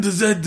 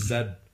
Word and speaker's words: Zed: Because the new movie is Pokemon X and Y and Zed. – That's right. It's Zed, not Z. Fuck you, America. Everyone else knows how it's pronounced, just like how everyone else understands that metric is the proper...